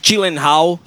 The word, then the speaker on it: Gyllenhaal: tem o Jake Gyllenhaal, que eu acho que é essa a pronúncia, né? – Isso.